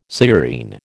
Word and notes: sciurine: American English

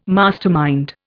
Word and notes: mastermind: Indian

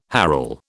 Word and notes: herl: British English

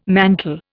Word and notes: mantel: Indian

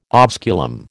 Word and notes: osculum: American English

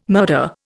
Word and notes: murder: Indian

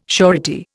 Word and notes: surety: Indian

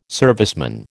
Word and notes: serviceman: American English

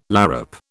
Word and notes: larrup: British English

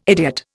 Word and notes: idiot: Indian

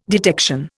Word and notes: detection: Indian

